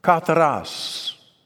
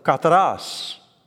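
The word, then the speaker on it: Kataras (καθαρός): Kataras. The accent's kind of on the last syllable. Kataras. I'll say it, then you say it. Kataras.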